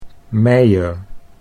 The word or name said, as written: Meier